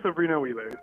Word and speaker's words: Sobrinho-Wheeler: It's Jivan Sobrinho-Wheeler.